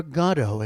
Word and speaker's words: Godot: That mountain waiting for Godot, or Godot as they've chosen to pronounce it in this production, by Nobel Prize-winning Irish playwright Samuel Beckett.